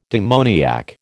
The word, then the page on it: demoniac: American English